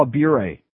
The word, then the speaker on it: Bure: How about pronouncing his name right? Okay, Brian, you're the spokesman. Pronounce his name right, all right? Just for your fun. Pavel Bure.